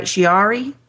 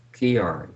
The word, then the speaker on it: Chiari: The affliction, known as, am I pronouncing this right, Chiari? – No, I think it's Chiari.